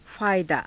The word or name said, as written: faayadaa